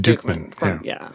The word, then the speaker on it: Duchman: So, when I see the label, it looks like Dutchman. – It does look like Dutchman. – But it's pronounced Dukeman.